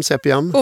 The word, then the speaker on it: Sepiam: Idag driver du det egna företaget Sepiam. – Ja. – Uttalade jag rätt? – Ja, Sepiam.